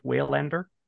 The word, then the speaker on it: Waylander: I believe that's how that's pronounced. Waylander. – Yeah, that sounds right.